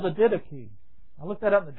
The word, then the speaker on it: Didache: And so, Webster says you pronounce it Didache. So, that's the way I'll pronounce it.